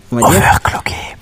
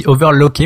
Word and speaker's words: overclocké: Tu dis overlocké, mais c'est pas ça le mot. – Ah bah si, c'est overlocké qu'on prononce, il me semble. – Cloqué. Cloqué. Bah, overclocké. Oui, il me semble. Parce que, c'est clock l'horloge.